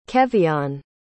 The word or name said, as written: keveon